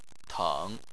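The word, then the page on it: teng: tone 3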